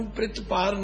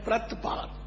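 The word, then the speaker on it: ਪ੍ਰਤਿਪਾਰ: सर्व को प्रतपार, इस अखर नू प्रतपार नहीं बोलना, एदा उच्चारण है प्रतपार।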